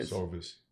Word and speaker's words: SOVAS: He is a Sovas Leadership, did I pronounce that right? – Sovas.